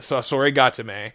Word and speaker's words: Sasori Gatame: The Sasori Gatame. Am I saying it right now? No. – Yeah, it not. – Sounds good. – All right, excellent.